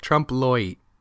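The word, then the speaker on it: trompe-l'oeil: You say it like Loy, but with a hint of an E at the end. Like Trump Loy.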